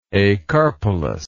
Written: acarpelous

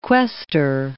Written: quaestor

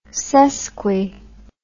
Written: sesqui

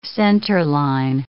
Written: centerline

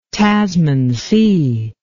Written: tasman sea

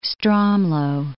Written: Stromlo, Mount

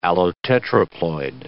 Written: allotetraploid

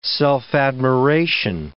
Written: self-admiration

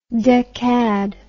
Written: decad

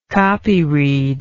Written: copyread

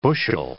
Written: bushel

